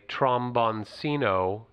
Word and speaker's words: tromboncino: And I learned that it was a tromboncino.